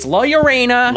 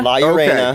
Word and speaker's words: La Llorona: Because I got made fun of incessantly for saying that wrong on Movie Dumpster. By the way, go check that out again. – Yeah. – By my girlfriend, by a fan. So it's La Llorona.